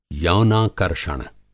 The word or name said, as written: यौनाकर्षण